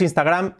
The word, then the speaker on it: Instagram: No, no es Instagram, es Instagram. Instagram, la I, una E, Instagram, Instagram. Letra clave, la I, una E. No es Instagram, no, Instagram.